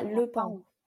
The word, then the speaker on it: Le Pont: there is an alcohol free shop in the 19th arrondissement called La Pont, excuse my pronunciation, La Pont qui boit. The Peacock. – Ah, Le Pont.